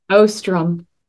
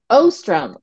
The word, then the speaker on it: Ostrom: Did I pronounce that correctly? – Ostrom. – Ostrom. Okay.